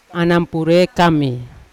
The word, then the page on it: anapure kami: Yawa